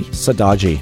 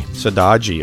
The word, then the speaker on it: Saudade: Sardadi? – Sadaji. – Sadaji, okay.